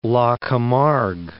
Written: La Camargue

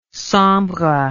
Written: sambre

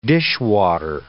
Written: dishwater

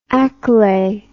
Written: akule

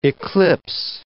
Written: eclipse

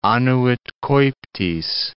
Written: annuit coeptis

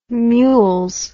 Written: mules

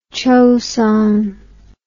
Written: choson

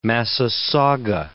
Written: massasauga

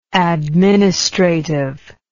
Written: administratively

